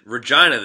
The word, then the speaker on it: Regina: I said Regina, the city that rhymes with fun.